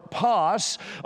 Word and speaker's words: pas: P-A-S would be the phonetic pronunciation of that, pas.